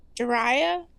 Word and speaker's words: Jiraiya: Is it Jiraiya? – Yeah.